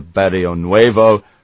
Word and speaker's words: Barrionuevo: Top goal scorers were shared between Kat Sharkey from the US and Noel Barrionuevo from Argentina. How was that pronunciation there? – That was John? – Very good.